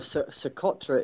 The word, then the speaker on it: Socotra: You went right by a couple islands, Scutra Island, is that how you pronounce it? Yeah. – And another Samha. And did you stop in either one of those? – No. Socotra is the island off Somalia.